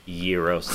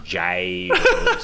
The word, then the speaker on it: gyros: And mad respect to Trey for pronouncing it Euros instead of Gyros.